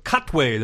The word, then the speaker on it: Katwe: Jim saw Queen of Katwe. Did I say that right? – Yes, you did.